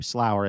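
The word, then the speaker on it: Slauer: Shelby is spelled S-H-E-L-B-Y. And her last name, Slower, is S-L-A-U-E-R. I get that right? – Nailed it.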